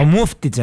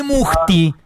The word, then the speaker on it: Μούφτιτς: Όχι Μούχτι. Ο Μούφτιτς. – Ναι ρε είπα Μούφτιτς. – Α, ακούσα ο Μούχτιτς. Αλλά δεν το λέει έτσι ο Μπορτάλι. – Ευτυχώς που τονίζεται στο μου. Δεν είναι στο φτις.